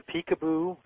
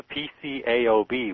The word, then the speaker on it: PCAOB: I understand you're one of the attorneys who have filed a lawsuit in federal district court challenging the constitutionality of, is there a way to pronounce this acronym, PCAOB? – Well, people call it the peekaboo as sort of a nickname. – Okay. – Technically, you can only call it the PCAOB, which doesn't roll off the tongue very well.